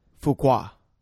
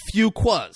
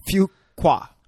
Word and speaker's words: Fuqua: Antoine Fuqua. – No. – What? – Fuqua. Like Fuquas. – That's what I thought I said. – You said Fuqua. Few. Like very few. – Fuqua.